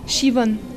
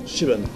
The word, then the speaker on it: Siobhan: Est-ce que j'ai bien prononcé Siobhan Owen? – Siobhan. – Siobhan. – Ouais.